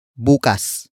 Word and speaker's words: bukas: And finally, we have bukas. Bukas. Bukas. Bukas is spelled as B-U-K-A-S.